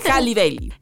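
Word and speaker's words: Halle Bailey: Que por cierto nos dijeron que se pronuncia Hailey Bailey. – Continuamos. ¿Cómo? – Haley. Hailey. Haley Bailey.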